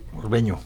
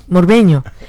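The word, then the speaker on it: Morbegno: Camilo Magoni nació en Morbeno, no sé si lo digo bien. – Morbeño. – Morbeño. Morbeño, Italia. Esa, la N se pronuncia como Ñ en italiano.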